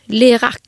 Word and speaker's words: Lirac: Och hur tror du att man uttalar det här? Lirac? Ja, det tror jag. Lirac.